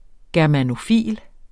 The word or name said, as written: germanofil